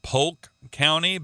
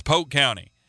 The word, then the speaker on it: Polk County: I used to call it Polk County, but my daughters both went to school there and said, "Dad, no, it's not Polk County. It's Polk County." And so they taught me the actual pronunciation of it.